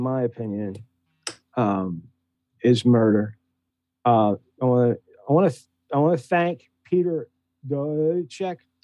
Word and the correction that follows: Dench: How do you say that reporter's name? Reporter. – Peter Dench. – What's that? – Dench. – Peter, I'm sorry. I apologize to Peter Dench. I wrote it down and then tried to pronounce it instead of listening.